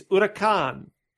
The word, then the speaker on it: Huracán: It's pronounced, that's flawless Spanish accent. It's Huracan.